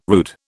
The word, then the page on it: rood: British English